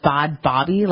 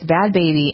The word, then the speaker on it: Bhad Bhabie: B-H-A-D-B-H-A-B-I-E or whatever? – I don't know. – Okay. So Bad Baby, who I used to pronounce, I thought it was Bad Bobby, like it was some kind of Australian accent saying Bad Barbie or whatnot. – Right. – But it's Bad Baby.